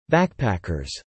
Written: backpackers

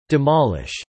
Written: demolish

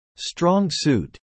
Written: strong suit

